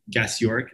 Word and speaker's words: Gasiorek: got a funky last name, Gasiorek.